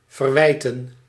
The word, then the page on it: reprocher: France; [ʁə.pʁɔ.ʃe]